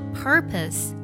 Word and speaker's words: purpose: 首先呢，目的这个单词我们读作 purpose，好多同学读作 purpose 或者读作 purpose 都是错的。这里呢，第二个音节 p o s e 读作 pus，pus，purpose 只有在第一个音节才可以加化音。